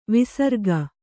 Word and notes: ವಿಸರ್ಗ: visarga

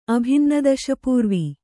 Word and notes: ಅಭಿನ್ನದಶಪೂರ್ವಿ: abhinnadaśapūurvi

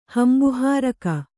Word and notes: ಹಂಬುಹಾರಕ: Kannada

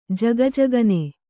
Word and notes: ಝಗಝಗನೆ: jhaga jhagane

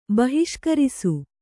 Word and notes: ಬಹಿಷ್ಕರಿಸು: bahiṣkarisu

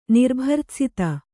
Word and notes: ನಿರ್ಭರ್ತ್ಸಿತ: Kannada

